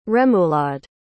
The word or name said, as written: rémoulade